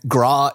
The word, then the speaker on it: Groch: It's spelled G-R-O-C-H, and I looked up pronunciation, and Graw is the best I'm going to do.